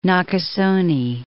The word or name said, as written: Nakasone